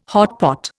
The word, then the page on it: hotpot: Indian